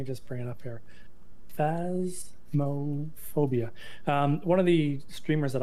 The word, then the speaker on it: Phasmophobia: A friend of mine gifted me, was it Phasmophobia? I think that's how you say it. – Yeah, that's correct.